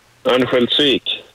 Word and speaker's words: Örnsköldsvik: Örnsköldsvik? How do you say that? – Örnsköldsvik.